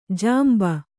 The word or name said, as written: ಜಂಬಿ